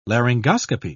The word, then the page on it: laryngoscopy: British English